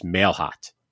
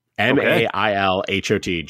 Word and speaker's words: Mailhot: And I he tells me it's the simplest pronunciation. It is Mailhot. – M A I L H O T, Jake Mailhot.